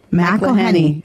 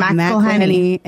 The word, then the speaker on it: McElhenney: Can you say it now? Now he threw his McElhenney. No. McElhenney. – Yep. Rob McElhenney and Ryan Reynolds.